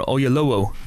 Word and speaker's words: Oyelowo: David Oyelowo, or Oyelowo, to actually pronounce his name correctly,